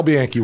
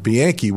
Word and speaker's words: Bianchi: Al Bianchi. When Bud Olson went into. – Sounds like two syllables.